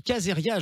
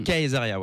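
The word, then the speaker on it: Caesaria: On dit Kazeria, je me trompe pas, hein, sur le nom. – On dit Kazeria, ouais. – Kazeria. – Ouais, c'est ça.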